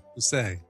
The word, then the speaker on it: Poussey: Yeah, Poussey is her name. – Poussey. – Yeah, that's exactly how you say it.